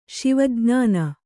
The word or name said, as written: ಶಿವಜ್ಞಾನಿ